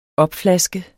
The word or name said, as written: opflaske